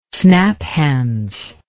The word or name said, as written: snaphaunce